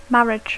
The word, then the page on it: marriage: English